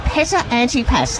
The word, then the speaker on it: Petta Antipas: And I'm catching up at the moment with Dr. Petta Antipas. How are you? – I'm good, thank you. – And I said that right, didn't I? – You did.